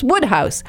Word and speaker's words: Wodehouse: And Wodehouse, which of course, P.J. Wodehouse is the author of the Jeeves books, is actually pronounced Woodhouse.